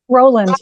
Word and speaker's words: Rowland: Rowland, yeah.